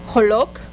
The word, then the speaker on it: Joloc: Bueno, vamos a aprender a escribir cómo se menciona Joloca en Yucatán, que es Joloc. Ajá, Joloc. No tiene ningún apóstrofe, por eso dice Joloc.